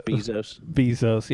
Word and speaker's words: Bezos: But, you know, so everybody's taking at least it, well, I guess some people, you know, Bezos is profiting. – Bezos. – What, how do you say? – Yeah. – Bezos.